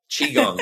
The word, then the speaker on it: Qigong: You know, folks, the hardest part about this practice is saying the name Qigong, because it's spelled Q-I, which, you know, is a translation from Chinese, but it actually is spelled more like C-H-I. Qigong.